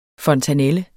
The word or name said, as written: fontanelle